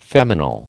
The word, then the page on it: feminal: American English